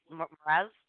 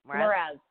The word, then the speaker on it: Mraz: You want to Jason Mraz? – Well, is that how you say it? Mraz? – Mraz. Jason Mraz.